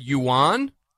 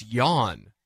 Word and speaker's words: yuan: I've always said yuan, but this dude really knows how to pronounce it. It's yawn. And so I yeah. – put in my notes, yuan equals yawn.